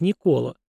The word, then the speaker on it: Никола: При этом на Руси до реформы патриарха Никона, книжной справы, имя святого писалось и произносилось как Никола, и его изменения подвергали критике старообрядцы, в частности, протопоп Аввакум в послании об иконном писании.